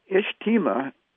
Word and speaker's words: Ijtema: And it's pronounced Ijtema, I-J-T-E-M-A.